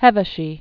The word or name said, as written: Hevesy